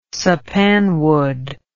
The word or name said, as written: sappanwood